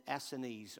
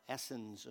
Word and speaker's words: Essenes: The Essenes, or Essens, as some people pronounce it.